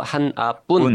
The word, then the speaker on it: 한아뿐: 제목이 지구에서 여기 발음을 주의해야 되는데요. 하나뿐. 음? 그러니까 여자 주인공 이름이 한아예요. – 한아. – 네네네. 네. – 지구에서 한아뿐.